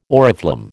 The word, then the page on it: oriflamme: American English